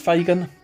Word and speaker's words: FGN: The name is stylized FGN, all in capitals, but pronounced Fagan, local boy almost.